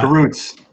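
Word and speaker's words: Kurucs: They got Oladipo, they got Dante Exum, uh, Karukis. Is that I'm not even sure how you say his name, but uh, Karutz.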